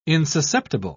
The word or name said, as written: insusceptible